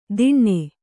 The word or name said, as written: ದಿಣ್ಣೆ